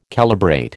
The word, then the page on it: calibrate: American English